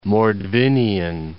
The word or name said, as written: Mordovian Republic